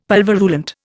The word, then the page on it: pulverulent: Indian